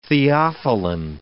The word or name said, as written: theophylline